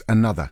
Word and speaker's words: ANOTR: Going, despite the fact that the act is spelled A-N-O-T-R, it's pronounced another.